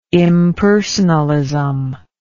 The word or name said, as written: impersonalism